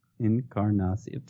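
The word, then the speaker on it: Encarnación: All right, how many syllables in Encarnacion? – Encarnacion is... Encarnacion. Five. – I believe that's right. – Yeah, that's right. – Good job.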